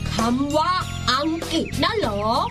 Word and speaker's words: อังกฤษ: วันหลังให้สังเกตให้ดีนะตัวรอรือต้องออกเสียงเหมือนรอเรือและออกเสียงให้ชัดด้วยคำว่าอังกฤษออกเสียงกอควบรอด้วยนะ